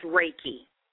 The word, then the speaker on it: Reiki: How do you pronounce that one? – That is pronounced Reiki.